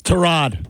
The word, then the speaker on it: Tyrod: What is it? You're my pronunciation guy. – It's Tyrod. – All right, Tyrod it is.